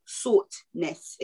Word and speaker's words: Sauternes: And I've probably butchered the shit out of the name, but I'm going to say it Sauternes, okay? That's how Google told me to pronounce it. So that's how I'm pronouncing it.